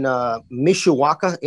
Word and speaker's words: Mishawaka: It's a mobile home park in Mishawaka, Indiana.